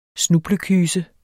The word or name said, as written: snublekyse